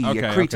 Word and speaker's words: Kretz: Actually, the drummer, Eric Kreetz, K-R-E-T-Z, Kreetz, I think is how it's said,